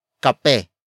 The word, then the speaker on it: kape: It is an object marker that introduces what this person recently finished drinking, and that is kape. Kape. Kape. Kape is spelled as K-A-P-E.